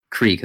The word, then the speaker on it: Krieg: Krieg, there you go.